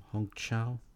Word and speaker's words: Hong Chau: Hong Chau? – Yeah.